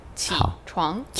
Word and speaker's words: 起床: 对，这个 third and second tone together is a very difficult combo for many people. So the first third tone, don't pronounce it too long. 起床。